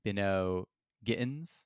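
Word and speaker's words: Bynoe-Gittens: Bynoe Gittens.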